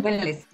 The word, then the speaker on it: Wellness: o cómo se pronuncia, corrígeme. Wellness, Wellness. Wellness.